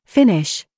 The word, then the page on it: FIFE: British English